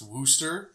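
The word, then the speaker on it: Worcester: Well, like Worcestershire sauce is actually pronounced Wooster.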